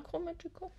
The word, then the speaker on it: Chromatica: Wie spricht man das aus? Chro Chromatica. Chromatica? Ja, Chromatica.